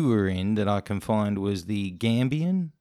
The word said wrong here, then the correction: Cambian: Is that correct? Is that how you say it? – The Cambian. – Cambian. – With a C.